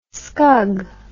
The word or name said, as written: scug